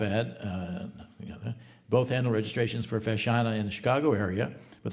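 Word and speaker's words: feiseanna: The plural is FESHANA, pronounced FESHANA.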